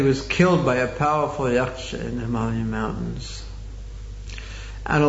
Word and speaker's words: yaksha: I've heard Prabhupada pronounce, maybe we can make this a little bit lower. The word yaksha, he called it a jaka.